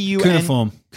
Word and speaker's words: cuneiform: Cuneiform.